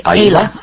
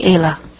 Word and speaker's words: Ayla: How do you pronounce it? – I pronounce it Ayla. I say Ayla.